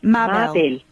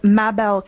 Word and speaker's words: Mabel: MABEL. – MABEL. Yes. – And your website is MABEL KATZ.